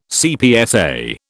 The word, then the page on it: CPSA: British English